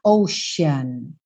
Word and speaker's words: Ocean: É muito importante a pronúncia dessa palavra, tá? Porque esse C vai ter esse som de X ou de SH. Ocean.